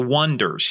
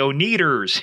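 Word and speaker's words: Oneders: But the band's name was spelled O-N-E-D-E-R for the Wonders, the Wonders. And everybody kept pronouncing it the O-Neaters. Here they are, the O-Neaters. The Wonders.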